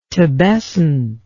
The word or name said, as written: tabescent